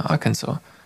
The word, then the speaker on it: Arkansas: Aber das ist ein S hinten noch dran. Ja, das spricht man anscheinend nicht. – Ah ja, Arkansas.